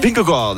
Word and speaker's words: Vingegaard: Vingegaard.